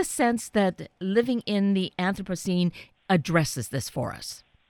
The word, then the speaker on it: Anthropocene: And I am so grateful because this new book, Living in the Anthropocene, and I'm saying that correctly, is that right? – That's right. Yeah. There are a number of ways to say it, but the way you pronounced it is fine.